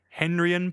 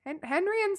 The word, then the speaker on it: Henrion: Like hen, like a female chicken. – Yeah. – Ryan, like Ryan Johnson, but with an O instead of an A. – So R-Y-O-N? – R-I-O-N. – Oh, H-E-N-R-I-O-N.